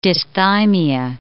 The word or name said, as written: dysthymia